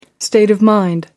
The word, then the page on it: state of mind: British English